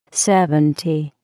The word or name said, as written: Seventy